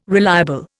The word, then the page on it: reliable: Indian